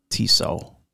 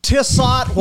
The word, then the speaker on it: Tissot: It's pronounced Tissot. – Oh, not Tissot? – Tissot watches. Merci beaucoup. Tissot watches his...